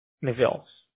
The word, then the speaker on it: Nevils: N-E-V-I-L-S. – Yes, yes. – How do you pronounce that? – Neville's.